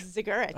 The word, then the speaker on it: Zergerich: Zergerich. – Ha, so you do pronounce the Z. Some people don't. – And the G. It's all in there.